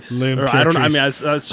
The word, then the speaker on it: L'Impératrice: By Les Impératrices. – L'Impératrice. – I apologize.